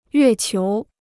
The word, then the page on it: 月球: yuè qiú